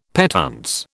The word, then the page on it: petuntse: British English